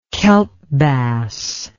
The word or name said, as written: kelp bass